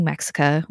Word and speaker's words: Mexica: And yes, I am saying Mexica with an A on purpose. I'm not just mispronouncing Mexico.